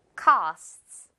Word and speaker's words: costs: co만 정확하게 발음 sts 속삭이면서 costs.